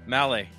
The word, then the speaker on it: Maleh: Maleh.